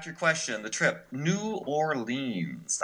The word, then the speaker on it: New Orleans: I've always pronounced the name of the city New Orleans, but I guess it's more like Nolens. – Yeah, but yeah, when you're in New Orleans, it's Nolens, N-A-W-L-I-M-S. Nolens. And I've got to say,